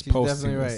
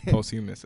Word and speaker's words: posthumous: She said pass though. It's posthumous. – Right. – It's posthumous, and I think I said that. – Posthumous. Posthumous. – I said post.